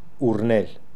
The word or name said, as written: Oornel